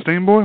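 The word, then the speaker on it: Steamboy: And that's the name of it, Steam Boy? Am I saying it right? – Yeah. I believe so.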